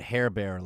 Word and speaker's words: Hebert: I think he said Hebert, like Hebert. – Silent T.